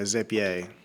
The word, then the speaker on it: Zapier: I think it's Zapier because the triggers are called Zaps. – Okay. – Yeah, they're not Zapes. – Gotcha. I pronounce it the French way, Zapier.